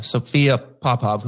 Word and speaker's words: Sophia Popov: Explain to the listeners, Sophia Popov, right? Mm-hmm. I say that right? – Yes. – Yeah. Sophia Popov, who won the Open Championship last week.